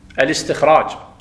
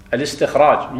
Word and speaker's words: الاستخراج: الانطلاق والاقتداء والاستخراج، مو الاستخراج، خطأ، الاستخراج، الاستخراج، الهمزة لا تلفظها، الاستخراج مو الاستخراج، الاقتداء مو الاقتداء، الانطلاق مو الانطلاق، همزة القطع طلعها من قلبك، الانطلاق، الاستخراج، لا الاستخراج، الانطلاق، لأنها همزة وصل جاءت في درج الكلام سابقتها ألف ولا.